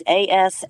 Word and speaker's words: Asmodeus: It was called Osmodeus. I'm not sure if I'm pronouncing it correctly, or Osmodeus. It's Asmodeus.